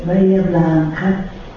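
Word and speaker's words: Bahía Blanca: fino a raggiungere Bahia Blanca, si pronuncia così, dove conosce una Lolita Echeverri, l'amore che lui definisce come più grande della sua vita.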